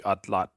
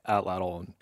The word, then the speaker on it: atlatl: The original word, you'd pronounce it something like atlat, and that's from the Aztec language, not what language. So we've anglicized it to atlatl.